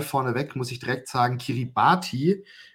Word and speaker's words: Kiribati: Das Land heißt tatsächlich Ki Kiribati TI gibt es im Kiribatischen nicht, äh, sondern oh. TI wird als S ausgesprochen.